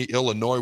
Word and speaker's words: Illinois: Number 1, Oregon just smacks around number 20, Illinois, where the S is silent, 38-9.